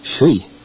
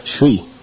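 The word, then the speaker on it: shui (水): How do you say water? – Now, how am I supposed to remember? I took this course 3 years ago. That's not very fair. – It's shui. – Ah. – No, shui.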